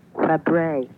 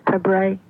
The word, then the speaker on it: Fabares: She spells it F-A-B-A-R-E-S. Does she pronounce it Fabre? – Yeah. – She does? – Yep. Shelley Fabre, yep.